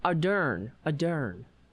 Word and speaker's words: Ardern: From, how do you pronounce? I always have a hard time with her name. Jacinda Ardern. Oh, no. Ardern. That's it.